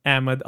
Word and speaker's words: Ahmed: Uh, and I actually had three Ahmad Ali Akbar.